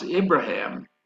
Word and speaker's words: Ibrahim: The name Abraham, they pronounce Ibrahim with an I.